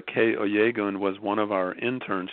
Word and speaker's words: Kay Oyegun: Kay Oyegun, O-Y-E-G-U-N.